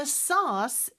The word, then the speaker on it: sauce: The sauce is boiling. 이라고 했어요. 자, 소스. 소스를 영어로 sauce. – sauce. 어 발음으로 발음을 하네요. 그죠?